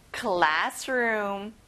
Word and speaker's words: classroom: 교실, classroom, class에 강세를 넣어보면 좋아요. classroom, excellent.